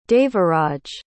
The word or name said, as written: Devaraj